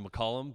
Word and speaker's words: McCollum: Yeah, it's actually McCollum, but that's okay.